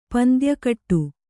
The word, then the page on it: ಪಂದ್ಯಕಟ್ಟು: pandya kaṭṭu